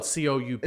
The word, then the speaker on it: coup: It's spelled COUP. – It's a coup. Yeah, a coup.